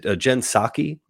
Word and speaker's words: Psaki: Is it Jen Psaki? Is that how you pronounce Jen Psaki's name? I think that's how you say it, yeah.